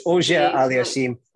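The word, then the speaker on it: Auger-Aliassime: And I can't pronounce Auxerre Aliassime. Felix. – Yeah. Auger Aliassime.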